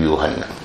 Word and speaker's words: یوحنا: یحییٰ علیہ السلام کا نام یوحنا استعمال ہوا ہے، نون کے بعد الف، یوحنا.